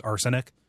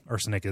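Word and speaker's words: Arsonyk: The name I use for any kind of like fantasy or sci-fi thing is just arsenic. Arson spelled like the crime and then YK at the end. Like, you know, like no good Nick or whatever. Yeah, arsenic is something that I do, and you know, it's a fun name.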